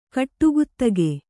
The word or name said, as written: ಗಡಿಗುತ್ತಿಗೆ